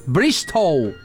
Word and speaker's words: Bristol: Grande do Sul, mas mora em Bristol, na Inglaterra, completando hoje 30 aninhos. – Brixton? – Bristol. – Bristol. – Bristol, com L no final.